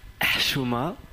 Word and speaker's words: Chouma: Je prononce bien Chouma ? – Non, vous ne prononcez pas très bien. – Je m'en doutais. – Ça se dit Chouma. Donc avec un H comme quand on a bu du coca.